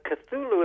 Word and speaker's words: Cthulhu: Uh, what is the Cthulhu um, mythos? – Uh, Cthulhu is actually a very good pronunciation of that.